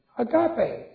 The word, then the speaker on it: agape: Agape, A-G-A-P-E, long E.